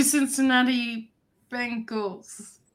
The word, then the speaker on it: Cincinnati Bengals: I go Cincinnati Bengals.